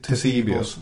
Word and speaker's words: Ctesibius: Men är det inte han som tillverkar orgen till, hur uttalar vi det? – Ctesibius, ja.